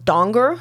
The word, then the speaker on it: Danger: And they're like, Danger, what a name. What a great name. And then he's like, are you Jake? Jake Danger. And he's like, it's actually pronounced Donger. And all I wrote here was Guy from The Hangover because yes.